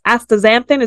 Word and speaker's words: astaxanthin: And one of them was astas- astaxanthin.